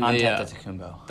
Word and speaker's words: Antetokounmpo: Giannis Antetokounmpo. I'm not ashamed. No one knows how to say his name. – And Antetokounmpo.